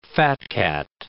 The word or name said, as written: fat-cat